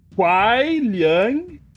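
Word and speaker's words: Kuai Liang: Agora, agora, agora pronuncia comigo, ó. Quai Liang, Kou Liang. Quai Liang, Kou Liang.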